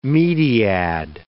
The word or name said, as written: mediad